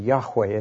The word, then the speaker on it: Yahweh: And the 4 letters are actually J-H-V-H, the 4 letters of God's name. That's pronounced Jehovah in English and Yahweh in Hebrew.